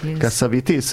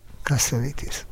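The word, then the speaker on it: Cassavetes: Te tudod, hogy kell Keszövetesz nevét kiejteni? – Keszövitis. – Igen, én mindig Keszövesznek mondtam. – Én is Keszövesznek mondtam. – Igen, egy amerikai barátnőmtől hallottam. – Ja, kiejtette, és így kell.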